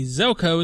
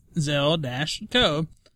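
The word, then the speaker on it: Željko: And the reason I'm saying it that way is because Jeff at the Event Wiki at replied me and told me Zelko is pronounced like Zell-Co. The J is silent. So, Zelko.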